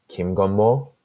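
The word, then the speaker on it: Kim Gun Mo (김건모): His name is, so it's spelled this way, so it's like if you just pronounce it off the pronunciation, it's like Kim Gun, like so Kim, which is like a pretty common last name, and then Gun, like the like I'm shooting a gun, and then Mo, M-O, and but like it's pronounced like Kim Gun Mo, right?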